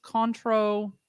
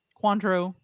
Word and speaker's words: Cointreau: Uh, Bols, Contro, Cointreau.